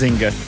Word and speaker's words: Nzinga: No, the N is silent. It's just Nzinga.